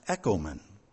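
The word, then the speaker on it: ekomen (ἔχωμεν): And that verb is pronounced ekomen. Huh? That's the same word you just said. No, there's just one letter difference, but it does pronounce the same.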